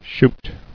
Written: Shute

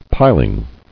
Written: Piling

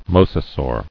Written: Mosasaur

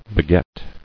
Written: Begetter

